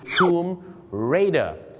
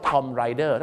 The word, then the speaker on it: Tomb Raider: แล้วกลายเป็นคำที่มักให้ดีในภาษาไทยไงครับผมหนังกันบ้างครับชื่อหนังนะครับบ้านเราก็ออกเสียงกันผิดเยอะนะครับแต่อันนึงที่ติดหูติดใจผมมากๆนะครับก็คือหนังเรื่องนี้ครับ Tomb Raider แต่บางคนนะครับไปออกเสียงว่า Tom Rider ถ้า Tom Rider ก็ผู้หญิงขี่ทอมถ้า Tomb Raider ก็ผู้หญิงขี่สุสานแล้วก็จะบุกไปขี่สุสาน